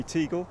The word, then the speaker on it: Teagle: Tommy Teagle, head gardener at Lanhydrock. – How do you spell your surname? – T-E-A-G-L-E.